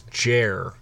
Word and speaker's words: Jer: His name is Jer. – Jer. – Jer. – Nice. – It's spelt jar, but he likes it Jer.